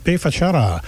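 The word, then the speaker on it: B Fachada: Before that, Bay Fachada, which looks like B. That's how they pronounce it in Portuguese.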